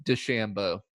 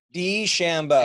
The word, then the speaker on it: DeChambeau: I've adjusted the way I pronounce his last name. I've decided it is DeChambeau. – DeChambeau.